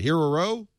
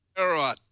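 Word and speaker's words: Heorot: Hererot. – Hererot. – Hererot, yes.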